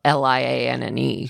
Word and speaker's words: Lianne: Taylor Lianne Chandler, and it's spelled Lianne.